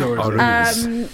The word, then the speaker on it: Auras: So Healthy is the new EP, which is coming in April, but that's following Auras, which am I pronouncing that right? Just because your face slightly I was like, wait, it's Auras. – No, it is Auras.